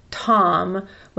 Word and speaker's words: Twm: And that was from her son, Tom, which is Welsh. So it's spelled T-W-M, and I believe it's pronounced Tom. – T-W-M?